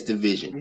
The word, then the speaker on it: DVSN: It's Division.